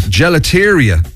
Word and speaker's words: gelateria: Gelateria.